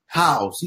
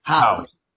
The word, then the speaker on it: Howze: Howze. You hit it. Howze.